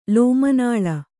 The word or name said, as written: ಲೋಮನಾಳ